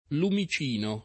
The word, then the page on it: lumicino: lingua italiana